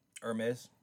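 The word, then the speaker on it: Hermès: Yeah, it is actually pronounced Hermes. – Yeah. – Hermes? – Yes. The H is actually silent.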